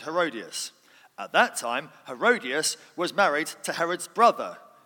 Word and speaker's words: Herodias: This was a territory that was controlled by Herod Antipas and his wife Herodias.